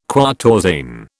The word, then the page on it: quatorzain: British English